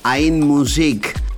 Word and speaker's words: Ein Musik: Lo que estamos escuchando es eh, Hernán Cataño junto a Audio Junkies esto salió bajo la etiqueta Ein Musik, así se pronuncia en alemán, el sello que fichó esta maravilla que estamos escuchando.